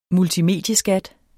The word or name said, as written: multimedieskat